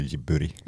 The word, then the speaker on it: Ghibli: Eh, i Japan säger de ju jiburi.